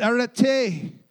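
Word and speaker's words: arete (ἀρετή): In the Greek, it's pronounced arete.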